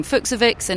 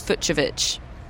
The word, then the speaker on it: Fucsovics: I had been saying Fucsovics, and it's Fucsovics.